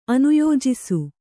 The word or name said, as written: ಅನುಯೋಜಿಸು